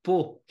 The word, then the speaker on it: Pau: Ele é de Pau, que é a cidade que se escreve pau na França, mas se lê Pau.